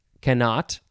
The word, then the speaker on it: cannot: I would pronounce it cannot. It is spelled C-A-N-N-O-T, cannot. You could also say cannot. You can emphasize the first syllable, cannot.